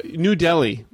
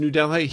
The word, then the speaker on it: Delhi: New Delhi. You know, everyone else in the world pronounces New Delhi. Here, it's New Delhi.